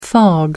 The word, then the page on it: fog: American English